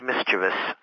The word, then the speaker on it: mischievous: the word is uh, correctly pronounced mischievous.